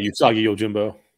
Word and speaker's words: Usagi Yojimbo: Yeah, Usagi Yojimbo.